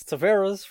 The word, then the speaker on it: Taveras: It's Taveras for everybody interested.